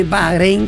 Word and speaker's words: Bahrein: De Bahrein, que se corre en Malasia. – Bahrein, me gusta cómo lo pronunciaste, bien ahí.